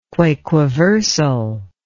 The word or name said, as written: quaquaversal